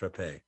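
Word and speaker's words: Rappe: By the way, her name was Rapé. It's pronounced Rapé.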